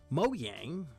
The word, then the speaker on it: Mojang: Mojang, that's how it's pronounced, said minimum specs are 4 gigabytes of RAM, and this man said, watch me.